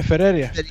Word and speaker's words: Ferreira: F-E-R-R. – Yeah, Ferreira.